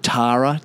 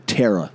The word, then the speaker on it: Tara: Tara, T-A-R-A, Tara, Tara. Couldn't get more straightforward than that. Terra, they say it. Terra, T-E-R-R-A, Terra.